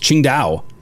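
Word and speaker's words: Tsingtao: It's pronounced Qingdao.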